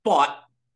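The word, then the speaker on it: Pfaadt: Is it Fought? – Fought. – Okay. – Yes.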